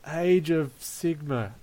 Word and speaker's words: Age of Sigmar: Yes, how did your Age of Sigmar? That's how you say it, isn't it? – Yeah, that's right.